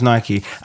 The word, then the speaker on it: Nike: Yeah, the pronunciation is Nike. It's named after the Greek god of victory, yeah. Greek goddess of victory, and her name was pronounced Nike.